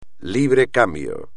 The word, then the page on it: libre cambio: Spanish